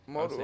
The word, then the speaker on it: Modu: Well, how do you pronounce his last name? It's Shy. – Shy Modu. – Modu, right? Modu. Yeah, I'll say Modu.